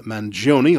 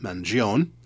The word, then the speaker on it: Mangione: Note that I'm pronouncing the name Mangione. A lot of people mispronounce it, Mangione.